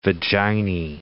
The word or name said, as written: vaginae